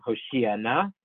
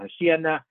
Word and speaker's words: Hoshia Na: So what you should say, you read the words in the Siddur, you would say, Ana Hashem Hoshia Na, and Ana Hashem Hatzichat Na. Stress the second to last syllable in Hoshia Na, stress the last syllable in Hatzichat Na.